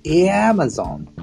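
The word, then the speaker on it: Amazon: って書いてあるじゃんって思っちゃうんですよ確かにでもですねとにかくあのアマゾンについては特に私の知る限り少なくとも西海岸のアメリカ人はあじゃなくて、えに近い発音から始まるんですよね。エアマゾンって言うと、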